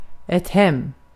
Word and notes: hem: [hɛm]

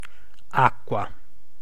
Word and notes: lane: /leɪn/

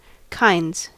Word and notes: kinds: American English

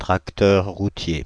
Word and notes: tracteur routier: France (Île-de-France); /tʁak.tœʁ ʁu.tje/

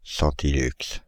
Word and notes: centilux: /sɑ̃.ti.lyks/; France (Île-de-France)

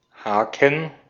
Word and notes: hook: American English; [hʊk]

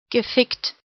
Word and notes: gefickt: /ɡə.ˈfɪkt/

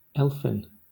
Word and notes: elfin: Southern England; /ˈɛlfɪn/

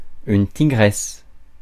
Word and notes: brave: American English; [ˈbreɪv]

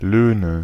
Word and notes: Löhne: /ˈløːnə/

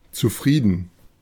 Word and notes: satisfait: France; [sa.tis.fɛ]

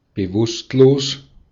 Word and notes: bewusstlos: /bəˈvʊstloːs/

